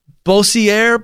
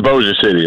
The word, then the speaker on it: Bossier City: Yeah, this is it's Bossier City. That's how you say it. Oh, man. I tried to get cute with the old French thing.